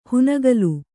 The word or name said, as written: ಹುನಗಲು